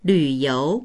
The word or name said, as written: lv3you2